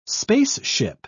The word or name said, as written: spaceship